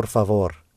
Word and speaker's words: favor: How many syllables are there in the word favor? – Tu favor. – And where does the stress fall, on the first or second? – Favor. On the second.